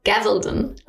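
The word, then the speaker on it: Gabaldon: Die ist geschrieben worden von Diana Gavaldon. Ich sag's mal falsch. Also schreiben tut man Gavaldon, aber sagen tut man Gavaldon. Also mit V.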